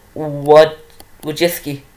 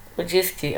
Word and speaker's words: Wojcicki: It is, you know, it's going to be hard to pronounce, but it is Susan Wojcicki. Wojcicki, okay.